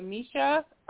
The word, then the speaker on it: Miesha: Shanna may see him coming, but uh, Miesha, I think her name—that's how you say her name. – Miesha Tate.